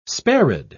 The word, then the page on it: sparid: British English